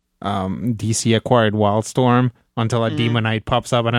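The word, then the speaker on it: Daemonite: It's actually pronounced Demonite.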